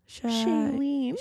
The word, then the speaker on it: Shailene: Oh gosh, how do you say what's her name's? Chanel? – Shailene. – Shailene. Oh yeah.